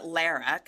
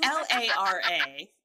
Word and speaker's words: Lara: Is her name Lara or Laura? – I pronounce it Lara because that's the least attractive way to say it. It's L A R A. Lara.